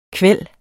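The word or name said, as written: KVÆLDEN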